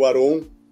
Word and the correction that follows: Arion: Uh, eu falei certo o nome dele? Falei. – Né? Não, né? Arion, né? Arion. – Arion, Arion. perdão, né?